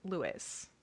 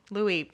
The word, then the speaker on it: Louis: is people who ship Harry and Louis. – Louis.